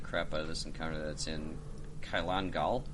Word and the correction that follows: Kalkingol: Kalkingol. – Kalkingol, thank you.